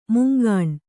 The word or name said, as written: ಮುಂಗಾಣು